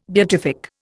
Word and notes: beatific: Indian

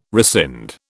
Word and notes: rescind: British English

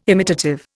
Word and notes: imitative: Indian

